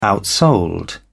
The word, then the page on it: outsold: British English